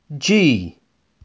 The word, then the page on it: G: Inglês